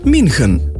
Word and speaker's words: München: Washington bomo izgovorili Washington, München pa München.